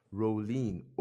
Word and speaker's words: Rowlene: R-O-W-L-E-N-E. – L-E-N-E. Rolene. Okay, Rolene.